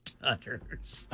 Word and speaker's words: Tutter: Tutters. – I've never. Tutter rhymes with truffle butter.